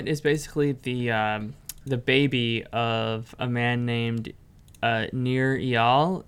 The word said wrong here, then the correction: Nir Eyal: Did I pronounce his name correctly? – No, you did not. – Please correct me. – It's Nir Eyal. – Eyal. – Although it's kind of spelled Eyal, but he pronounces Eyal.